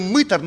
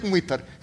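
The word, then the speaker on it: мытарь: Мы-то говорим мытар, но правильно мытар надо говорить. Но мы привыкли иногда вот так ударение неправильно ставить. Кажется, и дико звучит, когда оно правильно стоит. Потом пусть же будет мытар, как мы привыкли. А вообще правильно мытар.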